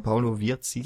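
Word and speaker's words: Paolo Virzì: Die süße Gier oder ihr Kapitale Humano von Paolo Virzi. – Das hast du sehr schön ausgesprochen.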